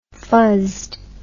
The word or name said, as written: fuzzed